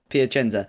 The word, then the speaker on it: Piacenza: Piacenza.